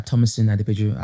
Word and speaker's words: Tomisin Adepeju: Yeah, I mean in, well, I'm on Facebook, uh, Tomisin Adepeju.